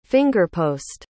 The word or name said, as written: fingerpost